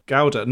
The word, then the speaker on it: Gouda: Gouda.